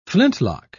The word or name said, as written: flintlock